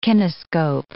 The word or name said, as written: kinescope